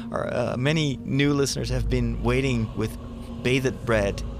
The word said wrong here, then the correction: bated breath: How do you say that? – Bated breath.